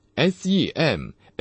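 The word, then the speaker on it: SEMAINO (σημαίνω): 晓谕这个词，我会念成 SEMAINO，它的史特朗编号是 4592，它是跟被翻译为征兆、标志的 SEMEION 这个希腊词的一个相关的词。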